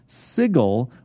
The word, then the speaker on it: Sigil: There is the city of Sigil, or as some people pronounce it, Sigil, because that's actually how it's spelled. I often like to make fun of how we pronounce Sigil, because it is spelled Sigil. – The creator of yes. Sigil. – But the official pronunciation is Sigil.